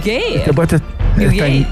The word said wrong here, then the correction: Wales: no. Wales. – Sí, que yo no sé pronunciar Wales, digo gays.